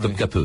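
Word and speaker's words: Topkapı: Ça s'écrit Topkapi, mais ça se prononce trop, top, pardon, je crois. Euh, hein, ouais. Topkape, c'est ça.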